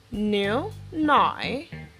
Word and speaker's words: 牛奶: New nai, new nai, new nai.